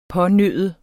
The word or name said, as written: pånøde